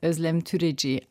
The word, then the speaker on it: Özlem Türeci: Also er heißt Uğur Şahin, also man schreibt es Ugur, aber das ist ein weiches G, Uğur Şahin und sie heißt Özlem Türeci, aber sie hatten sie richtig ausgesprochen.